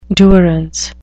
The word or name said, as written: durance